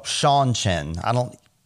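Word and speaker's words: Seanchan: So these are the Senshin. Shanshin. I'm terrible at pronunciations, but it's spelled like Sean Marciniak. Shanshin. Like Sean, C-H-A-N.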